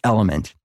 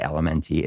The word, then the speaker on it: LMNT: It's Element. Spell L-M-N-T, but don't say L-M-N-T. It's pronounced Element, guys.